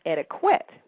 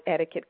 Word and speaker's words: etiquette: But the way to remember to how to pronounce this word correctly is remembering that since the word etiquette came from a French word meaning ticket, then the Q actually adopts the K sound. So it's etiquette.